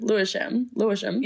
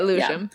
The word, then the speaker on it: Lewisham: And Bridget is getting ready for her live broadcast at the fire station in Lewisham. Lewisham. I think I said it right the first time. Lewisham. – Yeah, Lewisham. Yeah.